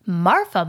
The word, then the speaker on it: Marfa: Marfa, Marfa, Marfa. – And for those of you who have not heard of this town, we did not forget how to pronounce Martha. There is a place called Marfa, spelled M-A-R-F-A.